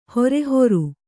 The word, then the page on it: ಹಾರಹೂರ: kannada